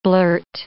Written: blurt

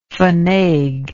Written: fainaiguer

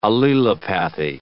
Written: allelopathy

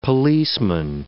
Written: policeman

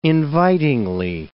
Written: invitingly

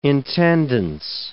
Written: intendance

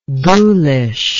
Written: ghoulishness

